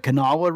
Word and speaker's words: Kanawha: It's located where the Kanawha River flows into the Ohio River. – Hold on. I want to applaud you on the proper pronunciation of Kanawha.